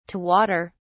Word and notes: to water: British English

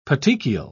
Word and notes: petechial: British English

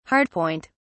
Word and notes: hardpoint: British English

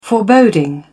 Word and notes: foreboding: British English

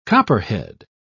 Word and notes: copperhead: British English

